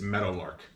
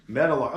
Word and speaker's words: Meadowlark: And to pronounce it right, it's Meadowlark. – Meadowlark. Oh, like Meadowlark Lemon. Okay. Yeah, Meadowlark. Like so, a former Globetrotter.